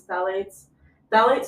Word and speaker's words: phthalates: So once again, the three P's, phthalates starts with a P, that's why I keep saying the three P's, it's just a silent P, so just to clarify that.